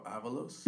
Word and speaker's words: Avalos: But it's just Adolfo Avalos. – Mm-hmm. – Adolfo spelled A-D-O-L-F-O. And then Avalos is spelled A-V-A-L-O-S.